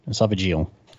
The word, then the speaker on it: esophageal: So no, esophageal.